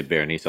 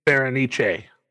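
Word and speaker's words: Bérénice: Best supporting actress goes to Octavia Spencer for The Help, beating out Bernice Bayo for The Artist. His name, I'm pretty sure I butchered. – Well, I was going to say Berenice also, so good job. – Thank you. – Berenice.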